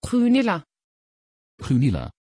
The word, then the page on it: gunilla: Dutch